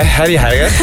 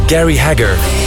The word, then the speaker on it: Gary Hager: Jij als West-Vlaming, hoe spreek je dat uit? – Ik hoor je al komen. Ik wist het, hè. Harry Hager. Harry Hager met... Nee, ik kan het ook perfect, hoor. Gary Hager.